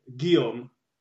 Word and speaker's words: Guillaume: Guillaume.